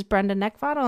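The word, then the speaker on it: Brenda Neckvatal: You can also find me on LinkedIn just using my name, which is Brenda Neckvatl, and that's spelled N-E-C-K, V as in Victor, A-T-A-L.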